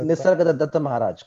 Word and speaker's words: Nisargadatta Maharaj: Nisargadatta Maharaj, correct.